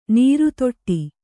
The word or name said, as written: ನರೆದೊಡು